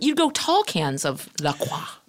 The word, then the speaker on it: LaCroix: Those assholes, if you go on their site, they say it's pronounced La Croix. – Sure. – Well, spell it C-R-O-Y then.